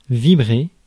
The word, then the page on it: vibrer: France; [vi.bʁe]